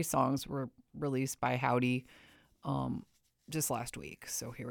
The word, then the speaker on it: Hovvdy: This is Howdy. It's spelled Hovvdy. So Hovdy, sometimes my brain goes to.